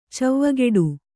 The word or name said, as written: ಚವ್ವಗೆಡು